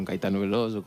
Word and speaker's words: Caetano Veloso: Y crecí pues con Caetano Veloso, con Jabá y demás, pero con Alejandro Sanz también. – Qué bien lo pronuncias, ¿eh? Caetano Veloso lo pronuncias de maravilla.